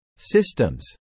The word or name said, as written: systems